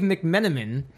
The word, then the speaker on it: McMenamin: Dave McMenamin, am I saying his name right? – Mm-hmm.